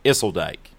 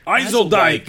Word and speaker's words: Ysseldyke: Okay, you all have the last part right, but the first part is very much wrong. It's Ysseldyke. – Ysseldyke!